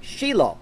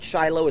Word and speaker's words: Shiloh: For many, many, many years, it was in a place called Shiloh. You might call it Shiloh, it's pronounced Shiloh.